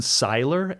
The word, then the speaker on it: Seiler: So there was a famous study done several years ago by an American named Steven Seiler, S-E-I-L-E-R, Seiler, I think is how he pronounces it, who now lives in Norway, I believe.